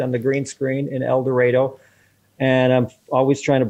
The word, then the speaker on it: El Dorado: Pronounced El Dorado when you're in Arkansas. Rhymes with tornado.